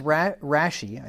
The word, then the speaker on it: Rashi: He says Rashi, I think is how you pronounce that, R-A-S-H-I, Rashi,